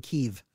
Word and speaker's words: Kiev: By the way, chicken Kiev is now pronounced chicken Kiev. – Did you know that? – Kiev is pronounced Kiev now.